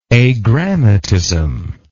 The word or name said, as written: agrammatism